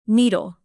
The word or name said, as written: needle